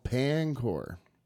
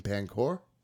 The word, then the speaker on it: Paincourt: I am going to be back in Canada in a town called Pancor, which, if you write it out, it's spelled Paincourt, but it's a very, very French town, so hence the name Pancor.